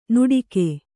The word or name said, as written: ನುಡಿಕೆ